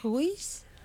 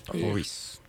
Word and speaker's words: Rhuys: Nous sommes de retour avec les deux co-créateurs de Cidre Ruiz. Je prononce correctement? Ça s'écrit comment en fait? – C'est R-H-U-Y-S. – Ruiz. – Ruiz. Ruiz.